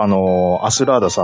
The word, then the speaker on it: アスラーダ: 僕、ちゃんとアスラーダさんの名前、アスラーダって読めるようになってます。はい。 – 当たり前の昔、アラスーダさんってずっと言ってたんですよ、僕。